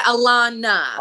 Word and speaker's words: Alannah: Yeah, Alannah.